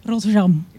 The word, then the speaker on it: Rotterdam: Okay, properly in Dutch, how would I say that? – Schiphol. – Yeah, and Rotterdam? – Rotterdam.